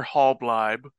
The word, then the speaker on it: Halbleib: So my name is Conor Halbleib. That last name is Hall, like a hallway, Bleib, like tribe.